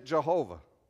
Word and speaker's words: Jehovah: I don't want to offend anybody, but it's taking those four consonants, dropping in the vowels from Adonai, and you get Yahweh or you get Jehovah out of it. And that's where it comes from in terms of our English pronunciation.